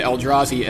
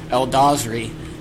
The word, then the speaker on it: Eldrazi: And Zvi keeps referring to the Eldrazi as Eldazri, and I swear he's got to be doing it on purpose. I know, because a couple times he actually pronounces it right.